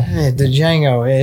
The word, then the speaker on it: Django: Django is gent. – The only thing more useless than the silent D is fucking udders on a male hog.